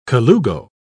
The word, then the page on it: colugo: British English